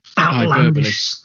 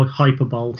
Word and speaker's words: hyperbole: Yeah, I was going to say hyperbole, but I wasn't sure whether I was going to pronounce it right or not, because for many years I used to pronounce it how hyperbole.